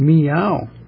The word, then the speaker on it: Miao: Miao, it's Miao. If we put it in English, meow, meow. There's no accent or no pitch indication on the second syllable, which is a diphthong, O-U, in English. That's unusual.